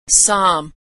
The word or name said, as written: psalm